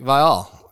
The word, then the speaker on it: Viall: How to pronounce Nick Viall. Yeah, Viall. – I've said it wrong the whole show. – I say it wrong sometimes.